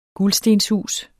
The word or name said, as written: gulstenshus